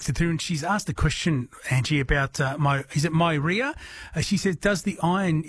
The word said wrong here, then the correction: Meyer: So maybe a Meyer lemon, so M-E-Y-E-R. – Yeah, that's it, yeah. Excuse my bad pronunciation of it.